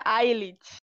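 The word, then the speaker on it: I'LL-IT: Ai, Ai, it, que sei nem como que eles pronunciam em coreano isso. – Se pronuncia Ailit.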